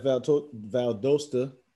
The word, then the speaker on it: Valdosta: I know you had great success at Valdosta. Am I pronouncing that correctly? – Yep.